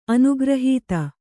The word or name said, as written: ಅನುಗ್ರಹೀತ